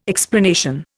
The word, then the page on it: explanation: Indian